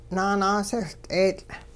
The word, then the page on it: naanaasehL'eetL': Hupa